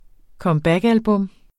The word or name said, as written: comebackalbum